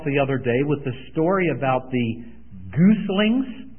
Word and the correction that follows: gosling: which many of you corrected me on? And the proper pronunciation is a gosling, I was told.